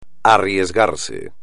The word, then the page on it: arriesgarse: espagnol